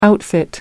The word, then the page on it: outfit: American English